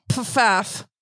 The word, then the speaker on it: Pfaff: Marianne Goldman and Stephen Pfaff. His name is spelled P-F-A-F-F. So I want to just make sure I really get the F's in there.